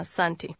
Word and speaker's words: asante: Nzuri sana, asante.